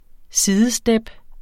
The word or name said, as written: sidestep